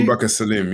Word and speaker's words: Abubakar Salim: This week on the Game Dev Show, I have the privilege of sitting down with an actor, Abubakar. Am I pronouncing that correctly? – Abubakar Salim, yeah.